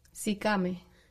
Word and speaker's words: Sikame: Sicame. – Sicame.